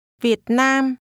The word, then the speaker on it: Việt Nam: Việt Nam. Nam. Việt. Việt Nam. Việt Nam. – This is pronounced with low, falling, broken, and mid-level tones.